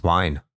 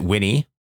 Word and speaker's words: Wine: What's wine? It's a thing that allows you to play Windows games on Linux. – Oh. – Mm-hmm. So, the, was it Winnie? No, it's just pronounced wine.